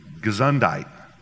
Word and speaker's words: Gesundheit: In the German, it's, how do you say it? Gesundheit, right?